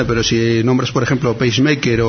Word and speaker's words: PageMaker: Es un paquete de autoedición para sistemas GNU Linux al estilo de programas comerciales como el Adobe PageMaker.